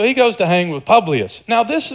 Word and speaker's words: Publius: New character now, Publius. Like this guy. He rhymes with Julius. Publius and Julius.